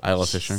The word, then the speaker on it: Isla Fisher: Isla Fisher.